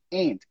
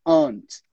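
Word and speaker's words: aunt: Se você tiver um sotaque britânico, como o meu, aí você vai falar ant. Aí é um pouquinho diferente. Mas a gente não pronuncia aquele U. Então, sempre dê um jeito de falar da sua tia durante o teste.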